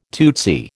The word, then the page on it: tootsy: American English